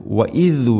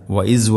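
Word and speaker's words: വഇദ് (وإذ): വഇദ് വഅദ്ന ഇവിടെ എല്ലാ ക്ലാസ്സിലും പറയാറുള്ളത് പോലെ തന്നെ ദാലിൻ്റെ ഉച്ചാരണം ദ എന്നോ ദ എന്നോ ആവരുത്. വഇദ് വഅദ്ന വഇസ് വഅദ്ന അല്ല വഇദ് വഅദ്ന വഅദ്ന ഐന് ഐൻ എന്ന അക്ഷരത്തിൻ്റെ ഉച്ചാരണം തൊണ്ടയുടെ മധ്യഭാഗത്തിൽ ആണ്.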